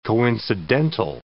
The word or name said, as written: coincidental